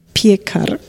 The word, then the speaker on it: Piekar: Dessen Namen du jetzt aussprechen darfst, weil ich habe ihn, äh, und da entschuldige ich mich mal, wirklich falsch ausgesprochen. – Martin Pierkar. – Das hören wir gleich nochmal. – Piekar. – Ja, also äh, ein polnischer Name.